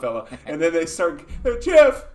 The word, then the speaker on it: GIF: And during his speech, he's like, I know everyone calls it a GIF, but I'm telling you, it's called GIF. Like, he's like... – It's a soft G.